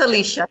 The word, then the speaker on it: Alicia: It's Alicia.